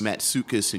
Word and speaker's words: Matsoukas: Matsoukas,